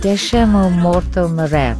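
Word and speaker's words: Deixa o Morto Morrer: There is a track called Deixa Mo Morto Morrer. Mm-hmm. Am I pronouncing that? Am I butchering that pronunciation? – In Portuguese, it's very hard to pronounce Portuguese, but it's almost perfect.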